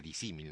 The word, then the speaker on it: disímil: Por ejemplo, disímil, se pronuncia disímil naturalmente, todo junto, significa algo así como dos cosas parecidas, pero separadas.